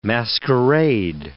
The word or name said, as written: masquerade